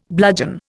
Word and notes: bludgeon: Indian

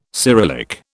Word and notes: Cyrillic: British English

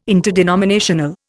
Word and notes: interdenominational: Indian

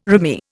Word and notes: rimy: Indian

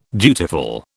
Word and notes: dutiful: British English

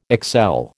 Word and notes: excel: American English

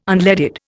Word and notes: unleaded: Indian

